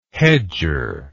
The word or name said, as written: hedger